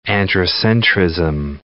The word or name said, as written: androcentrism